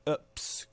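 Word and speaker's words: Epps: Ups, comme ça se prononce, dans son titre Goom.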